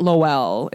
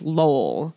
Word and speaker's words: Lowell: I kept saying it wrong. It's not Lowell. It's Lowell. – Right. – Lowell Farms or whatever. – Like and Lowell, then, Indiana? – Like Lowell, Indiana.